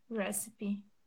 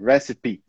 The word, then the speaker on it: recipe: Recipe. – Very good. Agora você falou certinho. Recipe.